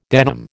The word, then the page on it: denim: American English